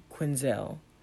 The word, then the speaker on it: Quinzel: Her last name is Quinzel. No, Harlene, I'm saying correct. The last one, Quinzel. It's Quinzel.